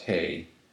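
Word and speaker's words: hey (ה): And the fifth section is hey, or H. And it's pronounced hey.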